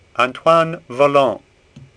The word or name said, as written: Vollon